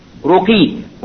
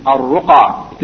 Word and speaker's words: al-ruqa: The word is spelled Ra Qaf Ya, and it should be Ra Qaf Ya without two dots, yani Alif Maqsura. So since the two dots was there, they wrote the name as Ruqi, when it should be Ruqa. The correct pronunciation is al-ruqa.